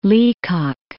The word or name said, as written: Leacock